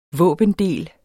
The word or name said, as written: våbendel